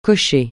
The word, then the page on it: cocher: francais